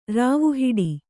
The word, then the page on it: ರಾವುಹಿಡಿ: kannada